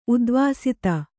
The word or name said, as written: ಉಧ್ವಸ್ತ